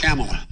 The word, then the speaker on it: Kamala: That's what I asked Kamala. – Her name is pronounced comma, like the punctuation mark. Look, Kamala.